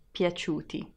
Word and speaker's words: piaciuti: And the last letter is I pronounced as I, piaciuti.